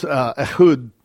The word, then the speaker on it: Ehud: And then in verses 6 through 12, we have the genealogy of Benjamin through Ehud, if however you want to pronounce it. It's fine with me. So in Hebrew, it's Ehud.